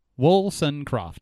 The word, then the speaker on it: Wollstonecroft: It's exactly how it looks. Wollstonecroft, Carol Lee Wollstonecroft, and she has been missing since August 4th of 1984.